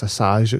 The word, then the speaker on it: Assange: And, I mean, the guy that runs it, or who started it, is Julian, and I'm sure it's Assange it's pronounced.